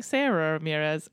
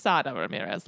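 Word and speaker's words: Sara Ramírez: The other thing is we keep saying Sarah Ramirez, and that is wrong. Their name is pronounced Sada Ramirez. Like, I watched a video of them explaining it, and they said Sada like tostada. And they go, and I know everybody knows how to say tostada.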